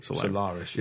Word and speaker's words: Solaris: Yeah, I mean, one of the first movies he did after Ocean's 11, speaking of Steven Soderbergh movies, is Solaris, or how do you pronounce that? – Solaris, yeah.